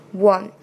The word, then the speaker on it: one: one，千万不要被这个单词的拼写所迷惑，因为它的发音跟 waking 是一样的，它前面还是个 w，w w，只不过后面它加了 r 和 n，r 是元音，短而小那个元音，n 是鼻音，念出来是 one one one two three。